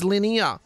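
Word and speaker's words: Linear: These boys did not pronounce their name Linnea as in the basic geometry concept. No, they pronounced it as Linnea, as if to rhyme with Shia, Chandelier, Billy Shakespeare, or so...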